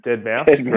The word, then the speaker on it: Deadmau5: Deadmau5, correct.